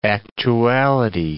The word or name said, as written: actuality